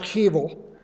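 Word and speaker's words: hevel (הֶבֶל): And it's pronounced hevel.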